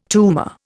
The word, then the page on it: tumour: Indian